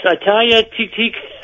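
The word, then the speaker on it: Atthaya Thitikul: And I can't pronounce her name. – Yeah. – But Titico. – Yeah. Yes, Ataya Titico.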